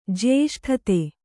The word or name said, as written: ಜ್ಯೇಷ್ಠತೆ